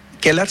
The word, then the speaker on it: Ckelar: Kelar se escribe CK.